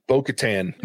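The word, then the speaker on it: Bo-Katan: How do you say her name again? Bokatesh? Is that how you? Bokatan. Bokatan. My apologies.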